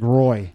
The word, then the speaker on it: Roy: It's spelled R-O-Y. – Yeah. – I would have said Patrick Roy.